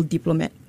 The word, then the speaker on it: DPLMT: So, if I bump her up, we all get bumped up because by association, we are all diplomat. – Yeah. – You know? – Interesting. We have the three uh, tri founders of Diplomat, which is spelled DPLMT, mm-hmm. uh, not the traditional spelling of Diplomat, in the studio mm-hmm.